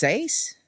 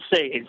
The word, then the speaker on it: Saze: You have, is it pronounced Saze? – I pronounce it Saze, yeah. – Saze. – Kind of like S-A-Z-E, phonetically.